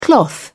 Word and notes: cloth: British English

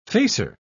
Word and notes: facer: British English

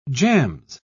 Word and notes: jams: British English

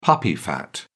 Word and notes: puppy fat: British English

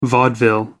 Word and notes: vaudeville: American English

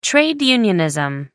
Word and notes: trade unionism: American English

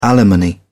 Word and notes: alimony: British English

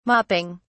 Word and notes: mopping: British English

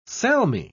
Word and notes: salmi: British English

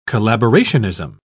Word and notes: collaborationism: British English

